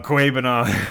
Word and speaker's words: Okwuegbunam: Okwuegbunam. That is the first and only time Neil is going to say his name correctly.